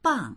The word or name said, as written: bang4